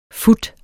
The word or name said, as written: fut